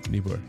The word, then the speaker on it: Niebuhr: Niebuhr, yeah.